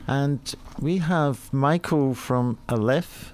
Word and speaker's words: Aleph: Did I say that right, Michael? – Yes, actually, you did. Aleph, however you care to say it, yes. – Okay. – That's the Scottish version. – That's the Scottish version, yes.